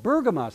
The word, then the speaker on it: Bergama: The city today is called Bergama. Sound like Pergama, because the Arabic language has a hard time with P's, so they pronounce it B, and so it's Bergama today.